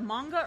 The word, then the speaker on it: manga: M-A-N-G-A. – Is it manga or manja? – Are you a fucking psycho? – I don't look at the shit. – It's manga. – Thank you. – The hard G.